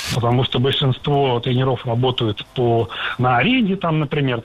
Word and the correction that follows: тренеров: Тренеров – это неверно. Слово «тренер» имеет неподвижное ударение на основе, поэтому сохраняем это ударение на первом слоге во всех формах. Тренеров, тренером, тренерами.